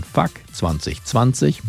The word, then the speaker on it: FCK 2020: Zum 30-jährigen Bandjubiläum ist in der vergangenen Woche jetzt die Dokumentation FCK 2020, ausgesprochen Fuck 2020 mit Bezug auf das bescheidene Corona-Jahr 2020, in den Kinos erschienen.